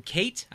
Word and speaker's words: Kait: We got one from, I believe it's a Kat, K-A-I-T, Kate, I think maybe is how you'd pronounce that, right? Kate.